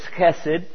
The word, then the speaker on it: chesed: So I usually spell it C-H-E-S-E-D, chesed.